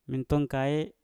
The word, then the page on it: minton kae: Mpur